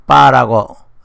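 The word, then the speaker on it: 빠라고 (八): 8자는 중국 발음으로 출발 또는 발전과 같은 빠라고 해서 행운이 일어난다는 뜻으로 받아들입니다.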